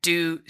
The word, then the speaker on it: Dieux: And they're in stock, and their Instagram, if you want to see, is D-I-E-U-X Skin. And I believe I'm saying that right, Do Skin.